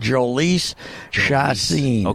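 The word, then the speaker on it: Jhoulys Chacín: I looked up a video and he's pronouncing it Yolis Chacin.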